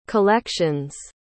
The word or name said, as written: collections